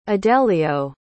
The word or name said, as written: adelio